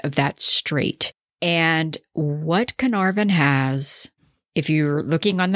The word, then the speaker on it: Caernarfon: This is the town of Caernarfon. Now, it looks like Caernarfon. It's in Northern Wales, but it is spelled C-A-E-R-N-A-R-F-O-N. And it's in it's a region of Gwynedd, G-W-Y-N-E-D-D.